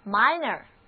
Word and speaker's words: minor: 注意哦，这里的字母 i 的读音不再是短的 e，而是 i 它的本音了， minor， minor。